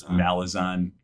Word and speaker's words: Malazan: Malazan book, Deadhouse, is it Deadhouse Gates or something? – Yeah.